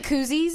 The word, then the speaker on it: koozie: Like it says that on the cozy? – Yeah. – Like, yeah. – It's like, you mean koozies? – It's, like, don't, koozies. It's a koozie. Yeah, yeah. Sorry, I mispronounced it.